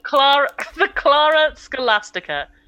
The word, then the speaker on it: Clara Scholastica: The Clara Scholastica. – Clara. – C-L-A-R-A. S-C-H-O-L-A. S-T-I-C-A. – Oh, like a scholar.